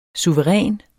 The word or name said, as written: suveræn